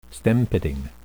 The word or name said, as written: stem-pitting